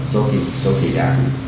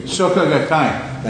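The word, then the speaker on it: Soka Gakkai: Soki Gaki. I mispronounced it for effect. Soki Gaki was a saying that they... – Soka Gakai.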